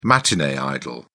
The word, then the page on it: matinee idol: British English